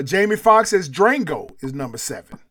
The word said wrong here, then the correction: Django: It's not Drango. – It's Django, ain't it? – Yeah. – The D is silent, ain't it? – Yeah, it's just Django. – I really butchered that, didn't I? – He's about to kill me! – I pronounced the D and put an R in there.